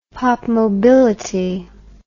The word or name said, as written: popmobility